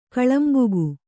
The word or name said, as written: ಕೋಲಂಬಕ